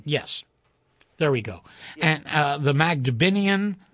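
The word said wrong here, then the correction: Mabinogion: Mabinogian, yes. – Thank you for saying it correctly.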